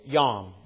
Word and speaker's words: yom: And again, Hebrew for day, yom, Y-O-M. You could say it rhymes with home if you want. This is yom is really how it's supposed to say if you want to get really technical.